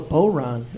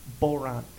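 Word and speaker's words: bodhrán: It's called a Boron. It's spelled B-O-D-H-R-A-N, but it's pronounced Boron.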